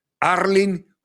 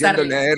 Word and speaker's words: Erling: Ah, ¿cómo es que vi cómo se pronuncia? Porque él lo puso en su Instagram, no sé cómo se llama. Arling Haaland. Arling Haaland. Y yo Sí, es Arling.